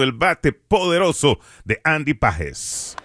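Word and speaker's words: Pagés: Y si escuchaste a José Mota y escuchas que dice de Pages, es que así es, así es que realmente se pronuncia Andy Pages, pero obviamente Andy pues ha dejado que le llamen Pages porque ya es de costumbre, pero correctamente es como lo está anunciando y pronunciando José Mota, Pages, que le está yendo muy bien a Andy Pages en esta temporada.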